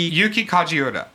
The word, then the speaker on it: Yuki Kajiura: Yuki Kajiura.